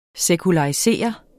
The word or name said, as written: SEKULARISERET